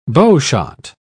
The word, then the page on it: bowshot: British English